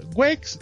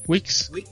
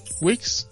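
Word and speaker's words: Weex: Por cierto, la doble E suena como I. Así lo pronuncian tanto las chicas de soporte como el conmutador. Entonces sería Wex Wix. Wix.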